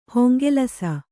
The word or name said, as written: ಹೊಂಗೆಲಸ